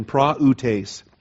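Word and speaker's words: praütes: And the umlaut is there. It's in the two little dots is important so that you don't make AU a diphthong. You give the vowel its own distinct pronunciation. Praoutes. P-R-A-U-T-E-S, praoutes.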